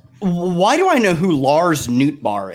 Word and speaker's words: Nootbaar: Lars Nootbaar.